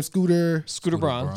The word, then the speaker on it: Scooter Braun: Scooter Braun.